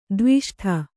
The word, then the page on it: ದ್ವಿಷ್ಠ: dviṣṭha